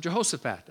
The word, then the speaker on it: Jehoshaphat: Jehoshaphat. Sometimes I'll pronounce him Jehoshaphat.